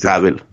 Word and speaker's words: Savile: Eller Savile, jag vet inte hur man uttalar det riktigt. – Savile. – Savile, ja. Mm.